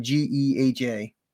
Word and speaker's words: GEHA: Well, it's Gia Field. Well, GEHA. They don't like when you call it Gia Field. They pronounce their company name GEHA.